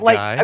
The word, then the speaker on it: Guy: I'm saying that right? – Yeah, you got Guy? – It. Yeah. Guy's correct.